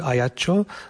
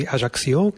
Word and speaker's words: Ajaccio: tady oni ho vyslovujú Ajaccio, Francúzi Ajaccio, keďže je tam francúzština oficiálny názov,